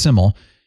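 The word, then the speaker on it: Syml: And then Simmel, it's S-Y-M-L, but it's pronounced Simmel. He did an awesome duet,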